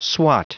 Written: swot

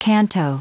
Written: canto

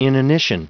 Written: inanition

